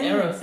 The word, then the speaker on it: Eros: Am I saying that right? – Right, Eros? Right. Yes, yeah. Eros, yep.